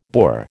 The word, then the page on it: boar: American English